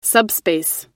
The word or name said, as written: subspace